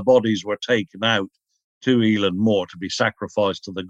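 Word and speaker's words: Eilean Mor: Is it Eileen Moore, the island? – Eileen Moore, it's pronounced.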